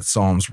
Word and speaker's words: psalm: The initial P in psalm is silent because over time the word was adopted into Latin and then into Old English. The pronunciation of the P was lost, but the spelling retained the letter.